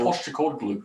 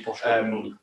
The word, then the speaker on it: Postecoglou: But he managed in the Japanese league, and Angie Postecoglou. – Yeah, exactly. Angie. – Angie Postecoglou.